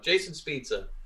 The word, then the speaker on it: Jason Spezza: I don't know why I always want to call him pizza. I don't know. Jason Spezza.